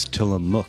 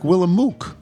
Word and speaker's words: Tillamook: Well, they don't tell you how to pronounce it. It just looked to me like Willamook. Yeah, Tillamook. Looked like a, looked like a mook. Like Willamette. I thought it was I thought it was Willamette when right.